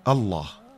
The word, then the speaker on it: الله: وقالوا: الله. الله مكون من الهمزة أو الألف، ومكون من اللام المشددة، لام أولى ولام تانية، ثم الهاء. أولا مخرج الهمزة أقصى الحلق.